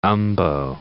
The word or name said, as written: umbo